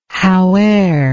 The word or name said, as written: howe'er